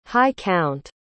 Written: high-count

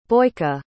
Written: Boyka

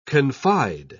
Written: confide

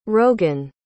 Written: rogan

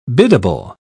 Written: biddable

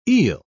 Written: eel

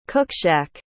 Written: cookshack

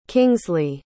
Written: kingsley

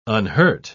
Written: unhurt

